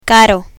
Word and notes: caro: spanish

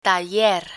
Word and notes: taller: Spanish

